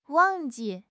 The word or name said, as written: wang4 ji4